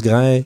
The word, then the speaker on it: Graj: Ça s'écrit impossible à prononcer, mais on le prononce Grai, G-R-A-J, donc J, donc Y pour les Nord-Américains, je suppose.